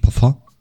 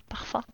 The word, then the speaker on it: Parfum: Sagt man Parfüm oder Parfum? Ähm. – Es kommt natürlich darauf an, aus welcher Breitengrad du kommst. Wir in Frankreich sagen natürlich Parfum. Parfum.